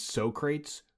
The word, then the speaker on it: Socrates: It's Socrates, thank you.